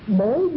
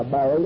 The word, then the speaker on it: bối: Ngay nơi cái tu hành của mình, thường thường người ta nhà Phật gọi là bối giác thiệt tành hoặc là bối tành thiệt giác. Cái chỗ cũng đọc là bội đó, bối bội đó.